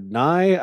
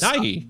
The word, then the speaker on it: Nighy: Is it pronounced Bill Nighy or Nye? I always Nighy. – Nighy? – Nighy. – I thought it was Nighy. I can't say I've like written him a letter and asked him. – Nye. I remember the end is Nye in Superman 2. There was a sign. That's where I first heard the word Nye. – Was it spelled the same way? – N-I-G-H only. And Nye. – Right. – So Nye Hi.